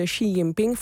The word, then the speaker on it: Xi Jinping: Ja, stop. Xi Jinping. Of Xi Jinping. – Ja, Xi Jinping, ja, begreep ik. Moet je het uitspreken. – Onze Chinese partijleider. Ja, precies. Ja, het is goed. Het is Xi Jinping.